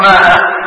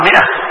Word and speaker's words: مائة: هذه الألف تكتب وما تلفظ، يعني تكتب هكذا ميم ألف بعدين همزة ها، لكن ما تقرأ مائة، تقرأ مئة، تقرأ مئة، تكتب الألف ولا تلفظ ولا تقرأ.